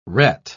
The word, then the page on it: ret: British English